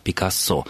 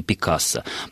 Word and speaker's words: Пикассо: Во французском, на всякий случай, напомню, стабильное ударение, и ударение всегда падает на последний слог, поэтому на французский манер эта фамилия произносится как «Пикассо».